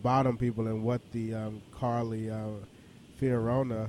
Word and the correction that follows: Fiorina: uh, I, am I'm, I'm totally mispronouncing her name. – Fiorina. – Yeah, Fiorina. Thank you.